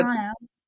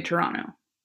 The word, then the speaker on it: Toronto: Yeah, so if you're from Toronto, you don't pronounce the second T. So Toronto. – It's actually Toronto, but like, mm-hmm. If you're from Toronto, you just say Toronto.